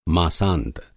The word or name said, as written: मासांत